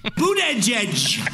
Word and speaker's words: Buttigieg: I kind of miss when Donald Trump was trying to say Buttigieg's last name. – Buttigieg. Buttigieg. Boot. Edge. Edge.